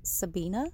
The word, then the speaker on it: Sabina: My name is spelled S-A-B-I-N-A. Some people pronounce it as Sabina. Some people pronounce it as Sabina. I respond to both.